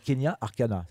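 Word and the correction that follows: Keny Arcana: c'est ça ? – Non. Kenny Arcana.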